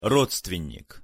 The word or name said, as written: rodstvennik